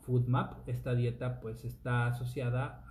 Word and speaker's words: FODMAP: Se escribe con F-O-D-M-A-P, FoodMap.